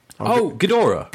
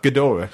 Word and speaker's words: Ghidorah: Yeah, it's a G-H-I Geodara. – Ghidorah. – Ghidorah, yeah.